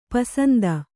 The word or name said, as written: ಪೂಸಂತೆ